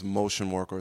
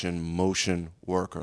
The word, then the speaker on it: Mocean Worker: The artist's name is Motion Worker. It's spelled MOCEAN Worker. So Motion Worker.